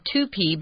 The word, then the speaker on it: toupee: It's T-O-U-P-E-E. It's a French word, so it's kind of weird. It looks like toupee, but it's toupee.